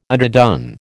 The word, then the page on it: underdone: American English